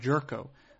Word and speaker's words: Gyorko: Jed Jerko. And I believe I'm saying that name correctly. It's Jed with two D's, J-E-D-D, and then Jerko, G-Y-O-R-K-O.